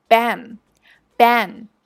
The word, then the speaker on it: bang: 第三个，The Big Bang Theory，生活大爆炸。它也是一部超赞的 sitcom。那在这里要注意一下 band 的发音，多数人会读成像拼音一样的 bang，但是错误的。要记住它的读法是 bang bang。